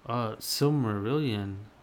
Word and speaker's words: Silmarillion: How do you say that? Cimmerillion? – Can I see that? – Cimmerillion? – Where is that? Where are you seeing that word? Silmarillion.